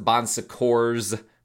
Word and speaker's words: Bon Secours: Some people pronounce it Bon Secours with an S at the end, because it does have actually an S at the end, but it's a French word. I think the S is silent. I don't know.